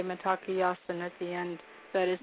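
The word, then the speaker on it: Mitákuye Oyás'iŋ: That's why we say Matakiyasan, all my relations. – Oh, man. How do you say it again? – Matakiyasan, Matakiyasan, all my relations.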